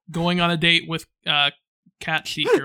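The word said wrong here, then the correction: Cait Sith: Kate Sith. – I know it's not pronounced like it looks.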